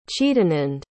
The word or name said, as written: chidanand